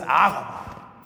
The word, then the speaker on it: agape: His kind of love is called agape, or we used to say agape. But it's funny how we pronounce the things the way we want to pronounce them. It's agape. Which means what?